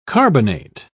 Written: carbonate

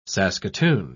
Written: saskatoon

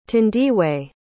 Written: thandiwe

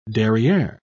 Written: derriere

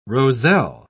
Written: roselle

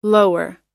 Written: lower

